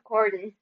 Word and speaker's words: Corden: I know they made Best Friends with James Corden.